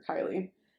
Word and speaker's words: Kylee: So, got the name Kylie Minogue for Kylie. But I spell it K-Y-L-E-E, and hers is I-E.